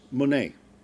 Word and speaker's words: mone: But this word is mone. M-O-N, long E. Mone.